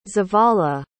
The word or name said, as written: zavala